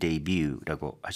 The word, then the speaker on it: debut: 그래서 대보다는 대이처럼 yeah. – debut. – So it's D-E-B-U-T, mm-hmm. not debut. – Right. 설마. 아, debut 안 된다.